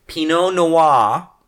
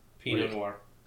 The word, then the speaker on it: Pinot Noir: Pinot Noir. – No. Pinot Noir. – Okay. – That one you actually say the R.